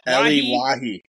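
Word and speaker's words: Elye Wahi: But Lens will equalize through, I'm going to say it's pronounced Elie Eliwahi.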